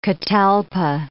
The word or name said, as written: catalpa